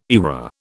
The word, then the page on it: eyra: British English